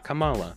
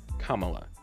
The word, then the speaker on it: Kamala: And he selected, and I hope I'm pronouncing her first name right, Kamala Harris. Not Kamala, Kamala.